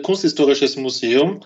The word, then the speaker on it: Kunsthistorisches Museum: It is the Kunsthistorisches Museum, Art Museum in Vienna.